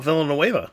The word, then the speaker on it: Villeneuve: Denis Villeneuve. – Oh, Villeneuve.